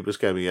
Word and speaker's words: Buscemi: Also, sticking with the Toblerone, the new Adam Sandler movie, Weekend Of, has Steve Buscemi, is that how you pronounce his name? – Steve Buscemi, yeah.